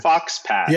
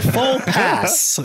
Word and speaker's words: faux pas: Fox pass. – Yeah, because it was like faux pass. And I was like, is this the fox pass? It's not. It's the faux pas.